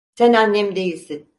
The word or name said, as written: annem